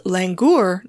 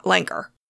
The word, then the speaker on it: Langur: The Dracula monkey is also called Miller's Grizzled Langur. Jumping in here really quickly to say I recorded this last night and just discovered that my pronunciation is wrong, as usual. It's Langur, not Langur.